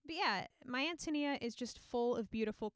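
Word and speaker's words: Ántonia: Yeah, it's pronounced Antonia for those of you who've seen the title and thought it was My Antonia.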